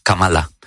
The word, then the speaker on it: Kamala: Porque Donald Trump lleva muchísimo tiempo llamándola Kamala, que es como no se debe pronunciar su nombre y eso a ella le enfada mucho. Entonces es Kamala.